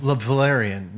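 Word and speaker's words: Velaryon: Valerian? How do you say it? Lord Valerian? – Valerian, yeah.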